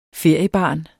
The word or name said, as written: feriebarn